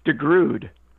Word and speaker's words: DeGrood: DeGrood.